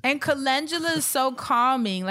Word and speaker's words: Calendula: Calendula. – Calendula.